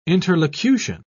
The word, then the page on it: interlocution: British English